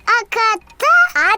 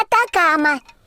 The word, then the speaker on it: Атакама: Аката? – Атакама.